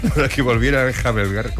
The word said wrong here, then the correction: enjalbegar: ¿cómo se dice? Enjalbegar.